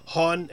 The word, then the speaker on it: hon: Okay, Sweden, this is going to be an awful pronunciation, but Swedish has two terms with she and he. Hon and Han, H-O-N-H-A-N.